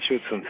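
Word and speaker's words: Schutzhund: Y del Der Schützhund, o como se pronuncia. – Del Schützhund, sí, sí.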